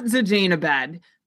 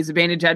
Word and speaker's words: Zibanejad: Mika Zibanejad, not Zajanabad. But look at these contextual clues from before and after injury. Before the injury, Zibanejad was playing 18 minutes, picking up around 2.5 shots per game, a little more than that, actually.